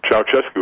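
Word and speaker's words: Ceaușescu: Ceausescu in Romania.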